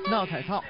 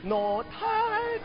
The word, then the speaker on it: not at all: 把目标定位在走向世界的帅哥黄晓明，英文似乎不太国际范儿，一句诡异的英语发音 not at all 被外界封为闹太套。 – 啊，因为 not at all 这种连读，就是他那个歌唱特别乱，是 not at all，就是他那个音乐就脱掉了。